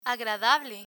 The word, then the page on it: agradable: Spanish